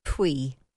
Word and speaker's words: Puy: P-U-Y. – Pui. – Yeah. Okay, pui.